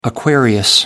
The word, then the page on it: Aquarius: American English